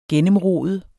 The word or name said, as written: gennemrode